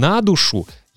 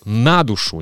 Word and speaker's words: на душу: И бальзам на душу я подчеркиваю на душу, не на душу, а на душу. Как вы знаете, в русском языке очень часто ударение падает на предлог.